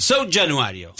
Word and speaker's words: São Januário: No es bueno mi portugués. São Januário. – Januário. – São Januário. Ahí está.